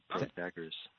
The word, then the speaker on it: Protagoras: You did it, Protagoras.